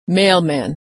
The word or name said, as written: mailman